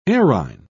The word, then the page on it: errhine: British English